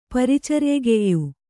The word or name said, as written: ಪರಿಚರ್ಯೆಗೆಯ್ಯು